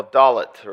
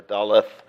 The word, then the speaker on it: Dalet: In the fourth, we come upon now Dalet, or Daleth, depending on your pronunciation scheme.